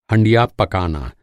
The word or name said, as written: हँडिया